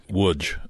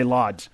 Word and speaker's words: Łódź: Yeah, because I would say Lodz. You know, trying to find the town of Lodz where the mall is that they go to. Well, nobody understands Lodz. And so I spell it and they say, oh, Łódź, Łódź. Same for the castle is K-S-A-I-Z, but it's pronounced Kieszaw.